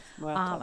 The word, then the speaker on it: Muertos: I think it's Dia de la Muerte. Muertos, I think it is. – Yes, Muertos.